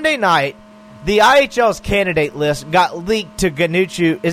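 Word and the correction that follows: Ganucheau: Am I saying his name right? Ganuchu? – No, Ganisho.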